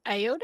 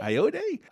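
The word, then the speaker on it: Ayoade: We got Billy Crudup, Will Forte, Richard Ayude. Am I saying that right? I gotta know that. I've seen him on so many stupid clip shows. How do you say it then? Ayude? – Ayode? – Ayode.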